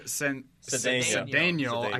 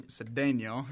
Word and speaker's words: Cedeño: Xavier Cedeño. I keep saying that wrong. Cedeño.